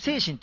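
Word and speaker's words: 西神: この手前が精神南の駅、精神って読むのね、はい、西の神と書いてね。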